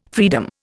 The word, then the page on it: freedom: Indian